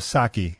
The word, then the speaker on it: sake: It's S-A-K-E. – Oh, sake. – Allegedly, the right way to say it is sake.